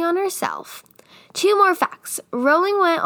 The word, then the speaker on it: Rowling: Her name is actually pronounced J.K. Rowling, but she doesn't care that people pronounce her last name wrong anymore, so she's J.K. Rowling to everybody.